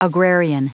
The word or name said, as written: agrarian